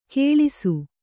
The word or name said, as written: ಕಳಸ